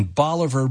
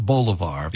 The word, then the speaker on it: Bolivar: You served as president of Southwest Baptist University in Bolivar, Missouri. – That's right. That's exactly right. Yeah. – Bolivar, not Bolivar. – Yeah, Bolivar. – It should be Bolivar, but it is pronounced Bolivar. That's right.